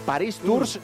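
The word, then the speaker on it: Paris-Tours: Por cierto, la prueba élite de esta Paris Tour, Paris Tours se escribe, Paris Tour se pronuncia Alexis.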